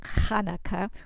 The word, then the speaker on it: Hanukkah: It is called Hanukkah, and you see Hanukkah spelled with a CH because that H is really a chet, so it's more like Hanukkah, which we don't have in English.